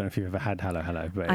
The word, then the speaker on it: Halo Halo: Yeah, and your studio is, it's Halo Halo. – Halo Halo, yeah.